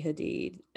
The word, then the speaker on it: Hadid: so now I forget the correct way to say their last name. – It's Hadid. – Gigi and, okay, cool. I think my sister says Hadid.